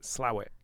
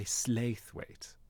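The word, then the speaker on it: Slaithwaite: So I'm from Huddersfield in West Yorkshire, and there's a place close by called Slathwaite. Okay. At least it's spelt Slathwaite. – Right. – And the locals pronounce it Slawit. – Oh, my God. – But then on the news, at one point... No, they say Slathwaite.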